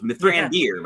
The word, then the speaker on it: Mithrandir: So it's Mithrandir, by the way, is the correct.